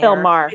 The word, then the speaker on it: Maher: He's like a talk show host, like something with Bill Mayer. – Bill Maher. – Bill Maher. – Oh yeah, I don't know how to say his name my whole life.